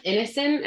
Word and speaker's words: Ineson: We've got Ralph Ineson.